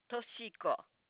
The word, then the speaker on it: Toshiko: First name is Tiazitam, O-S-H-I-K-O. Toshiko. – Okay. And last name, please? – L-I-T-S-T-A-M, G-S-G-B-R-A, L-E-R, Litra.